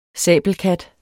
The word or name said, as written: sabelkat